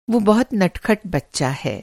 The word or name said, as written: नटखट